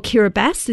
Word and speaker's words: Kiribati: She's from Kiribati, or Kiribati is how the locals say it, and she's in Australia to help bring our attention to the problem.